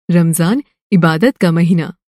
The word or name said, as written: रमज़ान